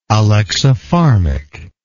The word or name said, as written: alexipharmic